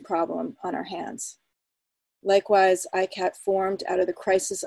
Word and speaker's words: ICCAT: So this is ICCAT, which is pronounced, and I'll use this throughout my introduction, is pronounced ICAT.